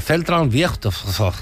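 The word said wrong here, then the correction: Zentralfriedhof: Central Friedhof.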